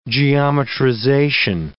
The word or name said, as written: geometrization